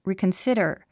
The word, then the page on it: reconsider: rìːkənsídər; リィコンスィダァ